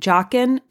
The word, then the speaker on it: Joughin: And Joughin, as far as I could tell, is the correct pronunciation. You will hear it read or said as other things, but I believe, based on the research I've done, that is correct.